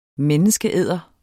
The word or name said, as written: menneskeæder